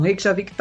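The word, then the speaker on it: Reykjavík: é, é, porque a galera que fala muito a pronúncia do português de Portugal, Reykjavik, porque esse J aí, ele é como I, né?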